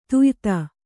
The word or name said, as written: ದ್ಯುತಿ